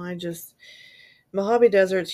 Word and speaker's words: Mojave: And then her last ping of her cell phone was May 23rd in I don't know how to pronounce the Mojave.